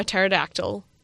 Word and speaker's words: pterodactyl: Why can't you hear a pterodactyl going to the bathroom? – I think I've heard this before. Is it because the pee is silent?